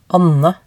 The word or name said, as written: Anne